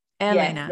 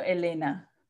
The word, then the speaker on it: Helena: I said Helena. It's Elena, right? – Elena. Yes, the H is silent. So Elena.